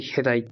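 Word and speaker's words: 平鯛: で、これ、えっと、ヘダイっていうタイで、平たいイって、日本語ではヘダイ。平たいって字にタイって書いて、ヘダイって読むんだって。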